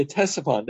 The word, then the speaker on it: Ctesiphon: Yeah, Ctesiphon. Now, in English, the C is silent. It's interesting, though, in the Gemara, you pronounce Ctesiphon, so it's preserving whatever language it was, and that was the capital of the Parthians near Baghdad.